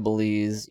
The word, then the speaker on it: Belize: Belize with a Z. – I know. I am. I just pronounce the Z like an S.